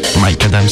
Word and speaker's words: Micadam: Mike Adam, c'est comme ça que vous prononcez Mike Adam, si si.